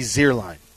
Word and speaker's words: Zierlein: It should be Zierlein. The second vowel is the long vowel.